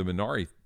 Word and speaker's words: Minari: It's not Minari, right? The Korean pronunciation is different now. We know that. – Yeah. Minari or something like that. – Minari, right. – The emphasis is on the other syllable.